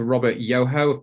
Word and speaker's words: Robert Yoho: I'm here today with Dr. Robert Yoho.